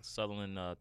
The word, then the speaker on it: Sutherland: Yeah, Sutherland.